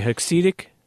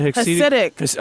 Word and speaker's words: Hasidic: Even, I was talking with a, how do you say, Hasidic? Is that a Hasidic? – Oh, excuse me.